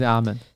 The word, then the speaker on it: Amen: 是念 Amen 还是阿门？ – 他影片里面是念 Amen，Amen，然后对，应该是念 Amen 才对，应该是念 Amen 才对，不然我们来再问一下 Google 小姐，好不好？